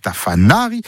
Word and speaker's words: tafanari: Un dernier point, vous avez remarqué que je ne dis pas tafanari, mais bien tafanari, avec un accent tonique, car oui, comme pour beaucoup de mots du parler provençal, il y a un accent tonique qu'il faut prononcer si vous voulez qu'on vous comprenne et si vous ne voulez pas trop vous faire remarquer.